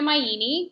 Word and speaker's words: Maini: So this is me, I'm Gina Maini. My last name rhymes with Linguini, so easy to remember that.